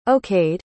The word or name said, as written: okayed